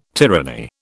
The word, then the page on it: tyranny: British English